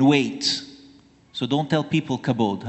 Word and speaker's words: kabod: The basic meaning of the Hebrew word for glory is kabod. I'm not sure if I'm pronouncing it right, but it's K-A-B-O-D when spelled in English.